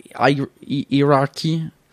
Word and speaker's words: hierarchy: Hierarchy. I don't know. – The point is that it's pronounced like the comparative word higher, high, higher, the highest, higher, higher, higher, rah. So there's a higher, hierarchy.